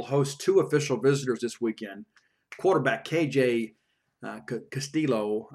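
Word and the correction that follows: Costello: I got to get used to that. Castello, I guess, is the right pronunciation. I've been saying, you know, Castillo. It's Castello.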